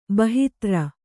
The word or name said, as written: ಬಾಹಿತ್ರ